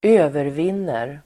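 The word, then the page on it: övervinner: [²'ö:vervin:er]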